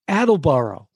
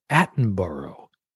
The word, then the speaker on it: Attenborough: And Attleboro. – First of all, it's a horrible pronunciation. It's Richard Attenborough. That name again? Attenborough.